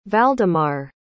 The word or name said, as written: valdemarr